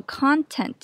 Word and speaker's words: content: Content 其实当名词也可以念作 content，重音放在第一音节，意思是内容或含量。